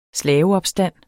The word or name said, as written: slaveopstand